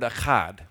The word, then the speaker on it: echad: The way he does it is brilliant, too, because the Hebrew word here is the word echad, which if you're going to say it right, you need to get a good ch in there, right?